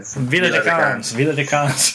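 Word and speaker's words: Viladecans: but Valladecans, or is that right? Valladecans? – Villa de Cans.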